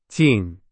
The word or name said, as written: 紧